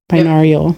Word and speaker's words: binaural: There's also, I know I'm going to say this word wrong, binaural, binaural yep.